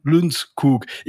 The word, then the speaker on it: Lundskog: Und in Schweden wird halt Johan ausgesprochen. Und das U wird dann, also bei Lundskog, das U wird ein bisschen wie ein kleines bisschen wie ein Ü ausgesprochen, also Lunds. Und dann hinten das O, Kug. Also Johan Lundskog.